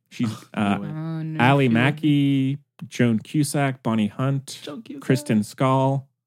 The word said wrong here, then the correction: Schaal: We love Kristen Schaal. – I like her too. I'm sorry. I'm mispronouncing her name. – Schaal. – Oh. Schaal. Like when it's a little blustery.